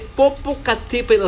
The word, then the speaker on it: Popocatépetl: Ahí está, o sea, escuchen la pronuncia, Popocatépetl, o sea, lo como es, Popocatépetl,